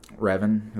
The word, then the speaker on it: Revan: Revan.